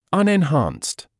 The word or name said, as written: unenhanced